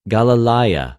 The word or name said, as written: Γαλιλαια